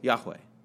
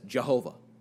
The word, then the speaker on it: Yahweh: And so, but it's just four letters for this name that God gives, YHWH, which we understand we pronounce now as Yahweh. If you read the King James or you read all the translations, it's how they used to translate as Jehovah.